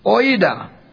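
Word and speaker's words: oida (οἶδα): Now here that the word is not ginosko, that common word, it is a word pronounced oida, O-I-D-A, oida.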